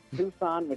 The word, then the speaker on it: Tucson: The pronunciation double D, not to call you out, not to throw shade, but it was a little off. It's Tucson, which is in Arizona, so it's the Arizona Coyotes.